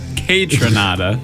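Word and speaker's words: Kaytranada: Okay, first of all, it's pronounced Kai Tranada.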